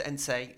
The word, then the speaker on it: Heinze: It does happen that players at Manchester United didn't Gabriel Ense, written Heinze, but pronounced Ense.